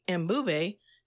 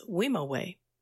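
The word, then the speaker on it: Mbube: Once it reached the U.S., the music industry never gave him credit and mispronounced Ambube to what we now know today as Wimowe.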